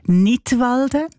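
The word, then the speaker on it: Knidwalden: Unter einem Titel mit einem Wortspiel Nidwalden, ausgesprochen wie der Kanton Nidwalden, aber geschrieben mit einem K vorne dran.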